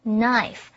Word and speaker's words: knife: 这个字比较特别。第一个是它的字首 KN 的 K 不发音念成 knife.